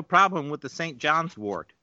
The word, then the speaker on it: wort: It's not pronounced wart. It's pronounced wort. – Wart? – Go ahead. Wort, not wart. Not like a wart on your hand. It's a wort, like a plant.